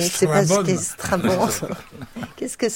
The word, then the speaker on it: Strabon: Oui, S-T-R-A-B-O-N. Ça se prononce Strabon.